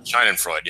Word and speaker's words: Schadenfreude: Schadenfreude, yeah.